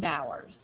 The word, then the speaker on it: Bowers: Charlene Bowers.